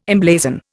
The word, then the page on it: emblazon: Indian